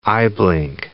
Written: eyeblink

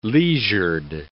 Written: leisured

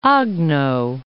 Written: Agno

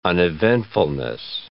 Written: uneventfulness

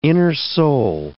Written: innersole

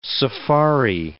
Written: safari

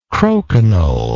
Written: croquignole

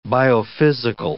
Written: biophysical

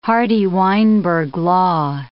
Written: Hardy-Weinberg law

